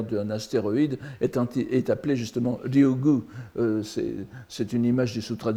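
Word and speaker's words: Ryugu (竜宮): Vous voyez, Shinryugu no Yukyo, entre parenthèses, Ryugu le palais, enfin on peut prononcer Ryukyu ou Ryugu, ici c'est Ryugu, n'est-ce pas, le palais des dragons, la lecture bouddhique.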